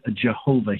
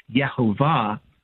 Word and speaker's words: Yehovah: Now, many say the word Jehovah here in the expression of that, but there is actually no J in Hebrew, and so it's sometimes more appropriately pronounced in the Hebrew as Yehovah or the Yod-Heh-Vav-Heh, and you're going to hear me talk about that a lot.